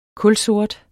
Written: kulsort

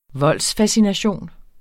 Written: voldsfascination

